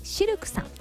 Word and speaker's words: CILQ: そして南青山にフレンチレストランシルクさん、 CILQ と書いてシルクさん、